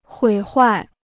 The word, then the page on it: 毁坏: huǐ huài